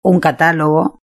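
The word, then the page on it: un catalogo: Spain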